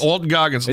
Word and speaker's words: Walton Goggins: Walton Coggins. Isn't that how you say it? – I think Goggins. – Walton Goggins.